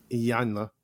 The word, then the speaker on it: Iyanla: Williams was talking about men and also women finding their divine femininity and then you know I- Ivana, right? Am I saying her name correctly? – Iyanla. – I- Iyanla, thank you.